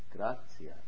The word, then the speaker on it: Gratia: Gratia, that's right. That's how you pronounce that.